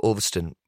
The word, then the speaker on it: Ulverston: And I'm from a small town called Ulverston. Where all my friends, it was... – How do you spell that? – U-L-V-E-R-S-T-O-N.